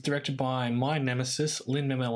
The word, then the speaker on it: Lin-Manuel: It's very easy to... Lin-Manuel, just say that.